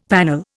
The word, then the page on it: panel: Indian